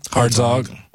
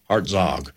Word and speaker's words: Hartzog: Hartzog. – What? – Hartzog. – Hartzog. Spell it. – H-A-R-T-Z-O-G. – Hartzog.